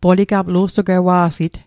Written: lu'sugwewa'sit

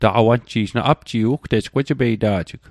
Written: taqawanji'j

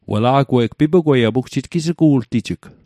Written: pipugwet

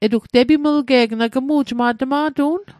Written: tepimlge'g

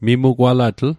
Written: mimugwalatl

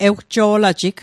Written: ewjo'lajig

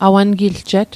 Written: awangiljet

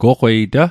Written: goqwei ta

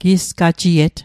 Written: gisgajiet

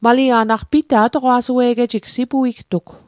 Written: atoqwa'sue'get

